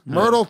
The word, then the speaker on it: Myrtle: I don't know what Myrtle Beach is. I mean, it's a beach, right? – Is there turtles there? Rhymes with turtles. Myrtle, turtle.